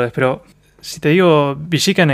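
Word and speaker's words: Billiken: Se pronuncia Villican, en realidad. Porque es en inglés.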